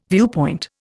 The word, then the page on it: viewpoint: Indian